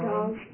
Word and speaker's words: Draughon: I know how to say it. – Yeah. – I think it was Drons, wasn't it? – Yeah, Drons. – D-R-A-U-G-H-O-N.